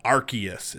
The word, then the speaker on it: Arceus: All right, first thing that came out last week, uh, January 28th for the Nintendo Switch, Pokemon Legends Arceus. Is it Arceus? Arceus? Think, is it hard C? – It's a soft C.